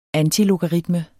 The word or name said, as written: antilogaritme